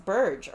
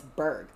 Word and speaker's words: Berg: Burge had a, pronounced Burge or Berg. I believe it's Berg. – I think it actually is Berg, if I remember the guy Berg.